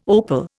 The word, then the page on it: opal: Indian